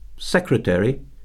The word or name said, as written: secretary